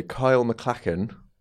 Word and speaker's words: Kyle MacLachlan: Trey, Kyle MacLachlan.